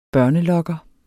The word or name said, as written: børnelokker